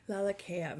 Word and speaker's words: Lalakea: Lalakea.